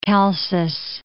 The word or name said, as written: Chalcis